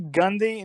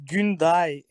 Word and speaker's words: Gunde: It's actually Gunde in English, but it's Gunday in Turkish.